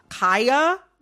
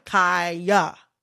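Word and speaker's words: Khia: I am going to, in this conversation, pronounce Kaya, the rapper, with the long I. Kaya, okay?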